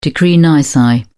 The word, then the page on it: decree nisi: British English